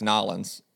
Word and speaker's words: New Orleans: you didn't say it correctly. It's Nolans. It's what? – Nolans. – Mm, yes, Nolans.